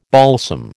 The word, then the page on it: balsam: American English